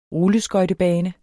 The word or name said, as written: rulleskøjtebane